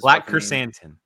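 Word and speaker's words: Black Krrsantan: I can't pronounce this. – Black Kersantan. – Thank you.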